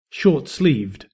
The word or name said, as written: short-sleeved